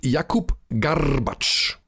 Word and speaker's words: Garbacz: Und Jakub Garbacz, das gerollte R, darf man nicht vergessen.